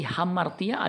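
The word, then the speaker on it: hamartia (ἁμαρτία): Kata dosa di ayat ini memakai kata Yunani hamartia bacanya hamartia tulisannya amartia artinya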